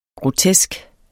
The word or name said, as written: grotesk